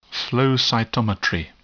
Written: flow cytometry

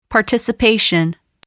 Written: participation

